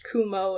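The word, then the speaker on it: Kumo (くも): But apparently one of the most well-known ones was summoning spiders instead of a cloud, because even though the characters are different, they're both pronounced Kumo in Japanese.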